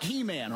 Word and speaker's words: Heman: And then you have a worship leader by the name of Heman, spelled like He-Man, all right? Masters of the universe. But his name is Heman.